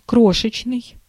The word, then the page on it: крошечный: /ˈkroʂɨt͡ɕnɨj/